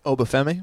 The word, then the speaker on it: Obafemi: Obafemi. – Obafemi, who finds Armstrong well.